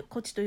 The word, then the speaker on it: 東風: 東風が東の風と書いてこっちと読むんですけど